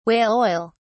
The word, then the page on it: whale oil: British English